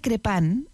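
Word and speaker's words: Crépin: Hubo momentos en que el padre, se pronuncia Crepin en francés.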